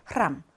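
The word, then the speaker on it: 흐람 (храм): X 발음은 당연히 아니고 명칭은 하, 실제 발음은 흐입니다. 흐. 성당, 사원. 여러분, 그 러시아 교과서에 앞에 표지를 장식하는 성 바실리 사원. 그때 그 사원을 나타내는 게 러시아어로 이거거든요. 흐람, 흐람이라고 발음하시면 되겠습니다.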